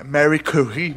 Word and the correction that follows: Marie Curie: Marie Curie.